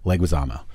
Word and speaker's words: Leguizamo: And I was like, oh yeah, I mean it was hard to let it go, because all of you Brits pronounce it as Leguizamo. Yes, it's Leguizamo.